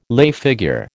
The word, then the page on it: lay figure: American English